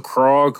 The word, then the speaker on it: Krog: Went to Krog. What is it? Krog Avenue, Krog Street. – How do you spell that? – K-R-O-G, like frog, but like with a K.